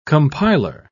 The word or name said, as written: compiler